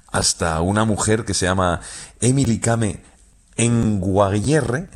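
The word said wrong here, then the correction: Kngwarreye: lo he dicho mal. – Emily Kame. – Con Guarreye. – Emily Kame con Guarreye, sí, sí.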